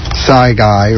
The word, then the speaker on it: Sci Guy: Sci Guy rhymes with Bill Nye.